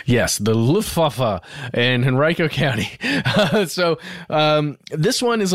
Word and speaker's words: Henrico: But what I did find is a decoy airbase that was constructed for real, fairly close to an airport in Richmond, in Henrico County. And that's how you say it, by the way. I thought it was Henrico, but it's Henrico County.